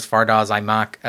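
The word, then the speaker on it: Fardaws Aimaq: Fardaz Aimak, I think that's how I said that right. – I think that is the pronunciation, yeah.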